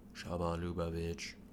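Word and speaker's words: Chabad Lubavitch: Shabbat Lubavitch. Shabbat Lubavitch. Okay, I said it right.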